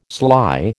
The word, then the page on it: sly: American English